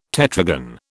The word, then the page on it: tetragon: British English